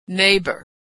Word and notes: neighbor: gh

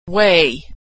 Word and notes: weigh: gh

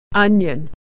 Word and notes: onion: y

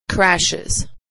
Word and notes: crashes: Iz

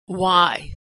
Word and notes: why: /w/

